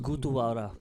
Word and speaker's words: Gurudwara: No, start going to a Gurudwara first. – And you need to stop. – And then just say that. – I can't pronounce that. How do you pronounce it? Gurudwara. – Gurudwara.